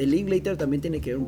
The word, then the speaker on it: Linklater: Linklater.